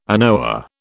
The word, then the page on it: anoa: British English